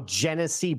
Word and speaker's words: Genesee: Is that how you say it? Genesee Brewhouse? – Oh, Genesee Brewhouse. Yeah, that's what did I say? – Genesi. I thought I was like, is that Italian place? No, Genesee Brewhouse.